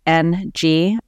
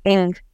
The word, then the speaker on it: Ng: Charles was born in Hong Kong, and the last name is spelled N-G. I'm not sure if I'm pronouncing it properly. – Yeah, you just say Ng.